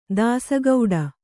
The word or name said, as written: ದಾಸಗೌಡ